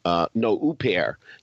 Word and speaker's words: Huppert: that's how it's pronounced, Huppert. Yeah, it rhymes with Blue Pear, that record label they used to put up bootlegs.